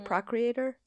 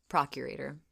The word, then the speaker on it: Procurator: How do you say it? Procreator? – Procurator.